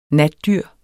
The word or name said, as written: natdyr